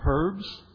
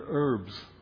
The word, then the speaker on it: herbs: Or as they say in UK, bitter herbs. – 要不就是说那个苦的食物，苦的菜。 – Americans don't say the H, bitter herbs.